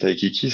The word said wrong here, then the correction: Taika Waititi: ça se passe là-bas. – C'est Taika Waititi. – Taika, pardon. Ouais, tu vois, je l'ai complètement écorché son nom. J'ai mélangé prénom et nom.